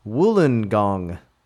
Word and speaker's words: Wollongong: To help you out, Wollongong is actually pronounced Wollongong, like your woolen sweater.